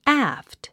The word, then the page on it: aft: ǽft; アァフト